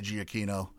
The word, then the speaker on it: Giacchino: Whoever was involved with that, and obviously Giacchino, as is the proper pronunciation of his name, he did the score for Up.